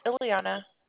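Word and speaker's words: Iliana: Sure, it's Ileana.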